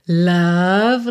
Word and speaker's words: love: É you love. Love, lembra de não pronunciar a letra E, tá? E o O também não é O, então não é love, love. Love.